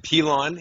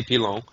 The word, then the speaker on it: Pilon: No, man. Pilon is is the most accepted way. If, if you want to get kind of French Canadian, you could say Pilon.